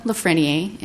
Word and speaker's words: LaFrenier: Okay, Linda Lafrenier, and my last name is spelled L-A, capital F-R-E-N-I-E-R.